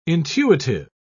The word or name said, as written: intuitive